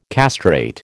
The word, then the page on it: castrate: American English